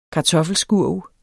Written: kartoffelskurv

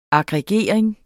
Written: aggregering

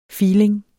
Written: filing

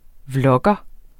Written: vlogger

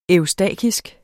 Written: eustakisk

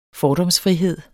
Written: fordomsfrihed

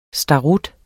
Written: starut

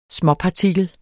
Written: småpartikel

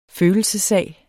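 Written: følelsessag

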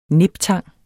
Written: niptang

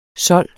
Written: sold

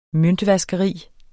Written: møntvaskeri

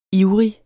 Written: ivrig

